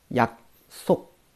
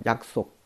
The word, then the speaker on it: 약속: 首先， 약 속，啊， 약 속，啊，两个收音都是那个。下面一个歌一样的是吧？嗯，那么这边需要注意了哈，读的时候呢有一个紧音哈， 약 속，啊，这个第二个字的话呢要读成一个紧音化哈， 약 속，啊，约会，表示约会约定都可以了是吧？